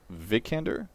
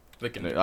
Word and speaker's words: Vikander: Alicia Vikander? Is that what it is? – Yeah. – I forgot her name already. – Vikander. – I don't even know how to say it once. Yeah, Vikander.